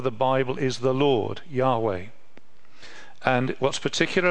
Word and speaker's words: Yahweh: In, if you were to pronounce it in Hebrew, the particular name that the God of the Bible has is Yahweh.